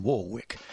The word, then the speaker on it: Warwick: I can't bring myself to use the American pronunciation Warwick. Ironically, her birth surname was Warwick, W-A-R-R-I-C-K. But when the label on her first hit, Don't Make Me Over, misspelt her name as W-A-R-W-I-C-K, she adopted it, presumably along with the pronunciation.